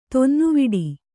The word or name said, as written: ತೊನ್ನುವಿಡಿ